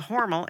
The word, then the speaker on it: Hormel: It's very famous for making Spam at the Hormel, and that is the correct way to say it, meatpacking facility.